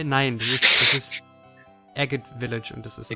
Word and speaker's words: Agate: Was ich verdammt genial fand im XD-Soundtrack, wo wir es eben angesprochen haben, ist Agathe Village und was war die andere? Phoenix City oder so? – Das wird übrigens Agate ausgesprochen. – Echt? – Das ist Oh. – das Englischwort für Ahart, ja.